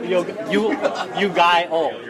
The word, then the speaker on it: Yu-Gi-Oh!: Yu-Gi-Oh! – Yes. That's how it's pronounced, correct? – Or Yogurto? – Yo-Gi-Yo. I'm going to have to defer to you on this one. I believe it's correct.